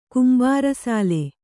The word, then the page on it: ಕುಂಬಾರಸಾಲೆ: Kannada